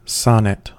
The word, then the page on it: sonnet: American English